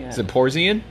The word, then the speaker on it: Zaporozhian: I can't even pronounce it in my head. Zaporizhian? Z-A-P-O-R-O-Z-H-I-A-N. – Zaporizhian? – There you go, sure.